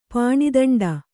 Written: ಪಾಣಿದಂಡ

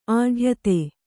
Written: ಅಡಿಯೊತ್ತು

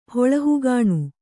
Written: ಹೊಳಹುಗಾಣು

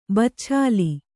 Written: ಬಚ್ಛಾಲಿ

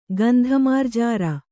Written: ಗಂಧಮಾರ್ಜಾರ

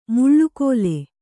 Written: ಮಳೆಗಾಲ

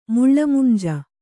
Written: ಮುಳ್ಳಮುಂಜ

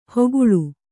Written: ಹೋಗುಳು

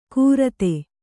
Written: ಕೂರತೆ

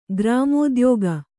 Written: ಗ್ರಾಮೋದ್ಯೋಗ

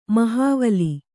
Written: ಮಹಾವಲಿ

